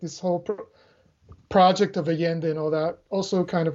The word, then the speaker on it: Allende: I forget that you don't pronounce the L's the way I just did in Spanish. – Oh, Allende, right.